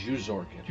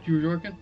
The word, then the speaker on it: Zhorkin: By the way, I found out how to actually pronounce that. It's like Jew, like Zhorkin. – Zhorkin? Yeah. Sorry, I'm having a hard enough time saying... – I don't think we're allowed to say that. – It's that hard J.